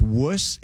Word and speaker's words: Worcestershire: Actually, you're right. It's Wuss. It's W-U-S-S-T-E-R-S-H-E-E-R. – You got so that's how you pronounce it.